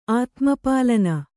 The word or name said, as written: ಆತ್ಮಪಾಲನ